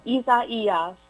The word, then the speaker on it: Isaías: So the I name is Isaías. That wasn't bad. – Isaías.